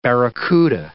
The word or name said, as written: barracouta